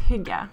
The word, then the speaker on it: hygge: But you have the rules on how to hugger. I told you I was going to say it wrong. How do you pronounce it once and for all? – Well, in Norwegian, it's hygge.